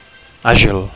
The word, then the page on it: agil: Catalan